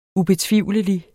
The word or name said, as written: ubetvivlelig